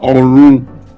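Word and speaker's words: Òrun: If my pronunciation is about Orun, which is heaven.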